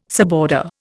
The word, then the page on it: suborder: Indian